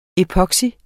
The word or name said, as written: epoxy